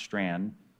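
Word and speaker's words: Strachan: I know it doesn't look like it's pronounced Strand, but it is pronounced Strand.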